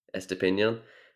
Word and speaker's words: Estupiñán: Estupiñán.